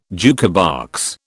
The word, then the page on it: jukebox: British English